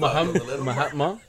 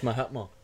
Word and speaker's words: Mahatma: It's like Jesus, Buddha, Mahaman, Mahan, Gandhi. – Hey! – What's his first name? Mahaman, Mahama. How do you say his first name? – Mahatma? I think it's Mahatma. – Yeah, Mahatma. Gandhi.